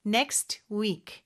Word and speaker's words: next: Next week인데 next의 요 T 발음은요. 사실 발음이 안 되는 경우가 많아요. 모음 이 아니라 자음 소리 사이에 낀 T나 D는 생략이 되는 경우가 있거든요. 그래서 여기서도 next week, next week 이렇게 발음할 수 있습니다.